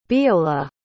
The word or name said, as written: Beolagh